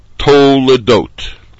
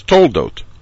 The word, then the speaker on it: Toledot: Toledot, first syllable, to. Tuf, cholam, by itself. The lamed with the shva belongs to the dot syllable, and therefore the word is properly pronounced to ledot.